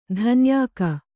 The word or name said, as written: ದಣ್ಣಾಯ್ಕ